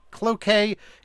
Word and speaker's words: Cloquet: Joseph with us from Cloquet in Minnesota.